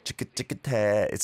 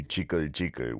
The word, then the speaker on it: 지긋지긋해: Yeah, again, if you mispronounce it slightly, it might go to jiggle jiggle, which is not a thing here in Korea.